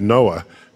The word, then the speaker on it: NOAA: It's fun because to pronounce that is NOAA.